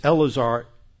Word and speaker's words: Eleazar: It's Eleazar. The English puts an extra E in the name, Eleazar, but there's no second E in the Hebrew. It's Eleazar. And it's like the second E in, or the first E in Phinehas.